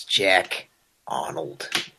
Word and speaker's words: Jack Arnold: It's Jack Arnold.